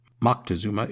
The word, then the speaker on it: Moctezuma: Specifically, I want to discuss the pronunciation of his name you will find many different versions of Montezuma's name. Moctezuma is very common, for instance. I am going to use Montezuma, which is very much a Western pronunciation. It is what I have grown up saying, and it is what I am most comfortable using, so Montezuma it is.